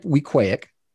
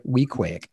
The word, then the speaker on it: Wequaic: Sure, Camp Wequaic. So it's rhymes with mosaic. That's the best way to say it. Camp Wequaic.